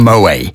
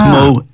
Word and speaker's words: Moët: Actually, I think one of the first things I ever learned on the slot was that it wasn't called Moe. Because a lot of people still probably call it that. You do pronounce the T. – Yeah, and well, there's the little two dots over the E. So you would that makes a two syllable word. So it's Moet.